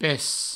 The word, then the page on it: pes: Provençal